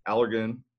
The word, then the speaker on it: Allergan: And he's participated in multiple FDA clinical breast studies and has been a consultant and speaker for Allergan.